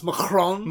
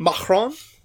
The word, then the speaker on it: Macron: And it's actually it's pronounced Macron. Macron.